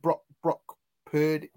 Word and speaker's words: Brock Purdy: Is it Brock Purdy? Is that how you pronounce his name? – For the 49ers? Yeah.